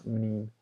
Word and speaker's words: Mneme: It's called The Meme, but it's spelled M-N-E, M-E, I think. Yeah, Meme. So M-N-E-M-E.